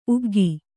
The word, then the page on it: ಉಗ್ಗಿ: uggi; Kannada